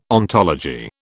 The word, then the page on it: ontology: British English